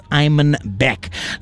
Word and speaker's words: Imanbek: It's spelled I-M-A-N-B-E-K. That's Iman Beck.